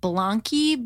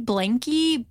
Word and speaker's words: Blanqi: And then I also just opened up this morning, actually, a pair of postpartum jeans from, I think the brand is pronounced Blanqui, Blanqui, B-L-A-N-Q-I.